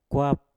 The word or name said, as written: kwap